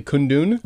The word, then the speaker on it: Kundun: Am I pronouncing this correctly? Kundun? – You are pronouncing it correctly.